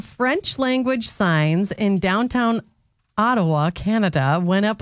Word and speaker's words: Ottawa: Ottawa. – Ottawa? – Yeah. – Okay. – Haven't you heard of Ottawa? – No, I haven't.